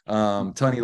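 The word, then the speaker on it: Tonne: Tunney like honey, T-O-N-N-E. I always forget how to say it, but Tunney like honey, yeah.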